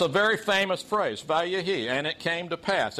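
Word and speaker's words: vayehi (ויהי): It's pronounced vayehi, and it means, and it came to pass.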